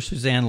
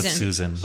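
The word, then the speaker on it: Suzan: Do we pronounce her Susan Laurie Parks or Suzanne Laurie Parks? – Susan. – I always heard it Susan too, but a podcast I listen to quite religiously always calls her Suzanne, and I yell at the podcast.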